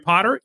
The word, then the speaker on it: Potter: My name is Jerry Potter. Yes, it rhymes with the boy wizard.